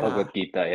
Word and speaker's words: Bhagavad Gita: Right now, I'm just getting into the Bhagavad Gita. I hope I have that pronunciation right. – Yeah. Bhagavad Gita, yeah.